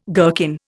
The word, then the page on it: gherkin: Indian